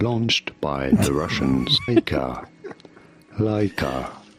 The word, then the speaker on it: Laika: It sounded like Laika. – Laika. – Laika.